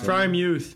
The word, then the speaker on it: Freiermuth: Is that how you pronounce Freiermuth. – It? Freiermuth. Freiermuth. Like, it could be him.